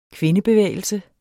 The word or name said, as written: kvindebevægelse